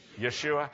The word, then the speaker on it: Yeshua: In Hebrew, how do you say Jesus? Yeshua.